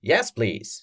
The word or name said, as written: yes please